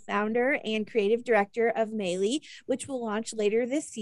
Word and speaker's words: Maylis: Maylis, yes, rhymes with daily.